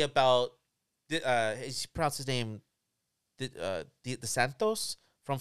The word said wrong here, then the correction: DeSantis: Florida, John, how you pronounce the governor's DeSantis. – Name? DeSantis.